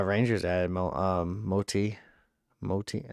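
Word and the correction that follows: Motte: that's how you say his name? Am I just losing my fucking mind? I would say Mott.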